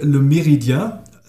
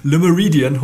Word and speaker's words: Le Méridien: Ich habe immer gesagt oder gedacht, es hieße Le Méridien, Marriott Hotel Le Méridien, aber ich habe gelernt, im Hotelfach wird auch das französische Englisch ausgesprochen. – Okay. – Also ist es Le Méridien Hotel in Hamburg.